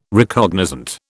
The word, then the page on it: Recognizant: British English